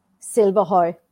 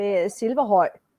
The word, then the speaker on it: Silverhøj: Silverhøj. – Silverhøj. – Silverhøj. Well, you know, if I would say it in Danish, it would be Silverhøj.